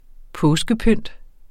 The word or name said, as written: påskepynt